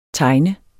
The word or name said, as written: tegn